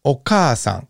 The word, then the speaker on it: okaasan: The second syllable, oka, is this first ka. And then we would add an a. Oka-a. We already got three syllables of this word, three letters of this word. We don't know sa, and we won't know un until the next few sheets. All right, so okaasan. All right, so if we look at kaki kukeko, you can go ahead and just practice these.